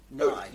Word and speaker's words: Nye: But not Nye, interestingly, not Nye. Nye. – Bill Nye. Okay. – Rhymes with sigh.